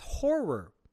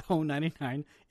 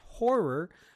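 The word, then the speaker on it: Ho99o9: They're pronounced Horror. That's how you pronounce their name, not Ho 99. It's pronounced Horror, or also known as Horror Death Cult.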